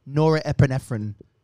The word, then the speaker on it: norepinephrine: It's norepinephrine.